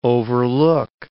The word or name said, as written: overlook